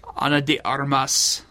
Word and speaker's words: Ana de Armas: Ana de Armas? – Ana de Armas.